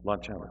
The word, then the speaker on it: Lanciano: Have you ever heard of a place called, I might not be pronouncing right, Luciano, Lanciano? Do you know the pronunciation of that? Lanciano, thank you.